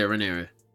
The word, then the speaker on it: Rhaenyra: Yeah, Rhaenyra.